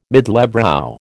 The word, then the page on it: middlebrow: American English